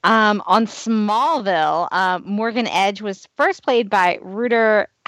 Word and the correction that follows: Rutger Hauer: How do you say his name? Is it Rutger? Rutger Hauer? Okay. I'm going to restart. – On Smallville, Rutger Hauer.